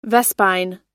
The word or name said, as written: vespine